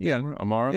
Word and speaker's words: Amaro: I also, a big problem I have is that it's all the, do you pronounce it Amaro? I'm about to pronounce it wrong. – Yeah. – Amaro? – Yeah.